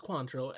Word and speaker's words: Cointreau: Yeah, it's pronounced Cointreau, and the person's last name, or the person who is the head of Cordon Bleu now, is André J.